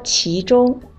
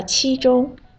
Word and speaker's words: 其中: 嗯，右边你这个“ 其中” 的发音不太准确，我得说一下啊，应该是说“ 其中” 二声“ 其”，你说成了“ 其中” 一声“ 七” 了。